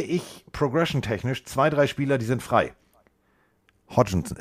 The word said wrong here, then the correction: Hawkinson: äh, wer, wer heißt denn der Titan? Ich kann es nicht aussprechen. Hawkinson. Hawkinson, ich sage immer Hodgkinson. Ja. Hawkinson. Hawkinson, direkt an der ursprünglichen Line of Scrimmage.